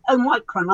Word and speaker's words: Omicron: Uh, and, you know, Omicron, I still call it Omicron because that's how I was taught to pronounce the Greek letter.